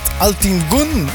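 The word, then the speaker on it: Altın Gün: En we begonnen dit tweede uur met Altin Gun, met Nere de Sinsen.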